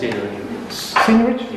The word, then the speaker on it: seigniorage: Seigniorage.